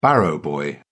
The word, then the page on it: barrow-boy: British English